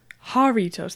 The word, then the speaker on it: Jarritos: Jarritos. That's how you say it.